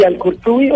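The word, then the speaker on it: Al-Qurtubi: Es sheikh y director académico encargado del Centro de Estudios Islámicos Al-Kurbu, dígame cómo se pronuncia, Al-Kurtubi de Bogotá. Sí, Al-Kurtubi o Al-Kurtubi. Bueno, el cordobés.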